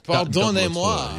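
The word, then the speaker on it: pardonnez-moi: Yeah, pardon moi. – A double tour. – Pardonnez moi. – Yes, to say it correctly.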